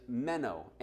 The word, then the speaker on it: meno: And that word, it says next spot on your notes there, is meno, M-E-N-O. M-E-N-O. And that's really how you say it. I looked up the phonics of it and everything. So it's not like a redneck trying to speak Greek, right? It's meno, actually.